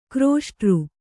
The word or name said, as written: ಘೃಷ್ಟಿ